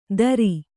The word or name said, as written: ತರಿ